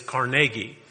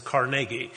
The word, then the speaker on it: Carnegie: But if you're from Western Pennsylvania, it is Carnegie. The university that bears his name and the city that was named after him is Carnegie. He came from Scotland, and the pronunciation of his name in Scotland is Carnegie.